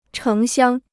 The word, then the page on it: 城乡: chéng xiāng